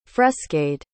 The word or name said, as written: frescade